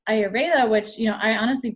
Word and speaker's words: Ayurveda: Oh, you can say like Ayurveda.